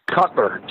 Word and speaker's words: Kuttler: Kuttler, just Kuttler, like Kuttler, Kuttler, but with a K.